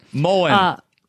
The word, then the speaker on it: Moen: Moen. – Moen.